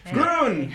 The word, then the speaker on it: Groen: Groen rhymes with moon.